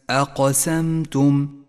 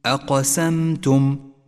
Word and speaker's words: أقسمتم: لاحظ صفة القلقلة لحرف القاف في كلمة أقسمتم، أقسمتم، واعلم أنها صفة قوة لهذا الحرف.